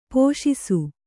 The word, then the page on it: ಪೋಷಿಸು: pōṣisu